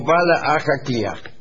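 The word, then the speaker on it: Baile Átha Cliath: Sí, dice, Dublín se dice Baile Ataquiat y se pronuncia algo así como Bala Ajaquiat.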